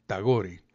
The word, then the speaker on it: Tagore: Tagore como suena, Tagore con G, Tagore. Rabindranath Tagore. No escriba esto, pero le cuento a usted que Rabindranath Tagore fue el primer poeta laureado, el primer poeta premio Nobel de la literatura del año de 1900.